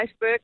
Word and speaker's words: iceberg: Altså, jeg vil sige, hvis man skal gøre det korrekt og følge ligesom reglerne for sprogene, så vil jeg sige iceberg, men jeg må indrømme, at jeg siger iceberg selv i sådan en daglig tale.